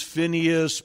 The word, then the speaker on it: Phinehas: and it's pronounced different ways. Phinehas, Pinhas, the Bible hero.